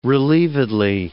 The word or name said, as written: relievedly